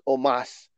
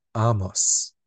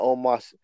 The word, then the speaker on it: Omos: I don't Anyway, Bobby Lashley and Omos had an arm wrestling match. – Is that how you say his name, or do you say it the way Bobby said it? I, Amos.